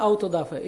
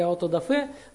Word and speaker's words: аутодафе: Слышали, наверное, такое слово, да, аутодафе или аутодафе, там, смотря какое ударение.